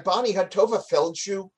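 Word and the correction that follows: Tovah Feldshuh: came in and did an episode playing the mother in the family, which was very exciting because I got to learn that I'd been mispronouncing her name for 30 years. Her name apparently is Tova Feldschuh. – Wow, okay. – Who knew? – It sounds so much less Yiddish.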